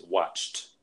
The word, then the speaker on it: watched: So with the first verb that I just mentioned, watch, the correct pronunciation in the past tense is watched. – Watched. – Right. So it sounds like a T, T sound instead of the ED sound.